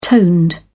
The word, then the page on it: toned: British English